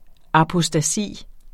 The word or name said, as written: apostasi